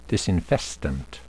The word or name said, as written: disinfestant